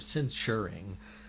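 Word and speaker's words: censure: And I'm trying to say it, pronounce it that way, because it's not, they're not censoring, they're censuring. I think I'm doing a little too hard on the SH sound in that, though.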